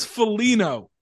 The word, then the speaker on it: Foligno: Did you also just pronounce the G in Foligno? Someone else did that when I was talking to them about this. Why is everyone saying that it's Foligno? – He's been around forever. Nick, he and his brother and their dad have been around forever. How do people still not know? It's a silent G. – All right, silent G. – His name is Foligno.